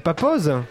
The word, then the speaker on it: Papooz: Hein, hein et tu dis papouze. Alors ça s'écrit P-A-P-O-O-Z. Pourtant, il y a deux O. Alors on dit papose.